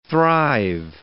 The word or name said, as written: thrive